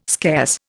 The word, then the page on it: scarce: Indian